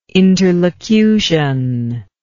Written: interlocution

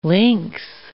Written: lynx

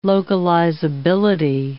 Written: localizability

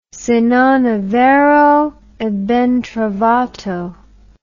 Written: se non e vero e ben trovato

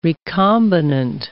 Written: recombinant

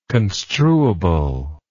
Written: construable

